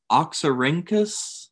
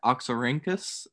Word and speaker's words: Oxyrhynchus: And maybe you know, Ross, how to pronounce this, but is it Oxyrhynchus? – Sounds good to me. – I like I said, I'm not entirely sure that I'm pronouncing that correctly, but it's spelled O X Y R H Y N C H U S. So I'm gonna call that Oxyrhynchus, and if you know better, you can correct me.